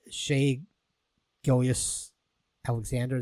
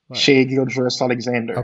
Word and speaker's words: Shai Gilgeous-Alexander: And we have a clip here of, I'm going to butcher his name, Shai Gilgeous-Alexander. Did I get that right? – Yeah, I think they call him SGA. That's his year. – Yeah, okay. – Shai Gilgeous-Alexander.